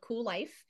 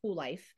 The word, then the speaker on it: Kuel Life: It is called Kuel Life. It is spelled a little weird, right? It's spelled with a K, so it's K U E L. But yes, the the platform is called Kuel Life, and uh, I got that off of the Urban Dictionary, Shilpa.